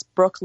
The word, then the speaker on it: Brookh: So if you want to friend me on Facebook, it's Brooke Lyons. It's spelled weird. It's B-R-O-O-K-H. And my last name is Lyons, L-Y-O-N-S.